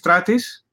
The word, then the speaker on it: Stratis: But my name is Stratis.